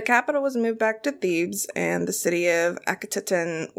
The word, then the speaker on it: Thebes: Thebes. – Yep. Okay, it's Thebes.